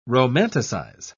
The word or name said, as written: romanticize